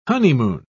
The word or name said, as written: honeymoon